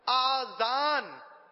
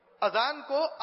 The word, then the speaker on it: اذان: بعض لوگ آذان کہتے ہیں، اذان کو آذان کہتے ہیں، یہ غلط تلفظ ہے.